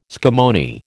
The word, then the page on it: scammony: American English